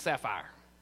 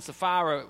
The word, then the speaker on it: Sapphira: When I was a kid, everybody called them Annas and Sapphire. So I mispronounced their name for like 30 years. In Acts chapter 5, Ananias and Sapphira, we learned that Barnabas wrote that check.